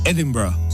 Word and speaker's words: Edinburgh: Sí, debes pronunciar la R bien fuerte. En inglés el nombre para Edimburgo es Edinburgh. Y así es como lo pronunciamos en Escocia.